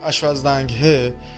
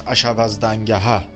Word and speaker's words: اشوزدنگه: اشوزدنگه که اون تلفظی هم که شما کردید غلط نیست در واقع تلفظ اصلی اوستاییش اشوزدنگه هست که به معنای درستکار حقجو در عشا پایدار هست آها بله.